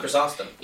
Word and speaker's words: Chrysostom: Chrysostom.